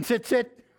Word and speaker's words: tzitzit: T-Z-I-T-Z-I-T-H. Tzith. Can you stand up here? Okay, this fringe here. Tzitzit. What is it? – Tzitzit.